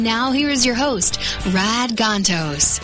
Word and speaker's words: Rad: And we welcome you to The Rad Life, the only show with Rod Gontos. I know it's spelled Rad, but we pronounce it Rod.